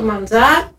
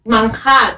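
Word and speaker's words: Manjar: Γι' αυτό το λόγο και δημιουργήσαμε το Μαντζάρ, το οποίο Μανχάρ κανονικά προφέρεται, να αναφέρω.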